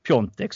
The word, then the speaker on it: Piontek: Så att jag faktiskt skickade iväg frågan här tidigare till min kusin som bor i Polen och för att beskriva Piontek som ni helt korrekt uttalar honom.